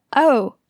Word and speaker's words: o: 10:07 的零读作 o，而不是 zero。